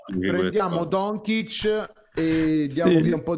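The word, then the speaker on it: Dončić: Allora, c'è però una cosa, bisogna che insegniamo come dire, come pronunciare correttamente il cognome di Luca. Potresti, allora, Luca Doncic. – Vabbè, Doncic. – No, Doncic. – Doncic, prendiamo Doncic ed è fatta. – Applauso! Per la prima volta da 7 mesi a questa parte ha detto Doncic e non Doncic.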